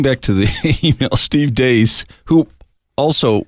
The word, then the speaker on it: Deace: Because it's spelled D-E-A-C-E, but it's pronounced Dace.